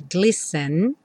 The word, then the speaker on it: glisten: Where the treetops glisten, como se fossem dois S's, glisten, and children listen.